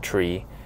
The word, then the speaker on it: trie: The T-R-I-E, pronounced tree, stands for retrieval.